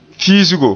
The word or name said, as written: fiisgo